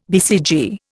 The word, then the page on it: BCG: Indian